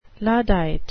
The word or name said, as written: luddite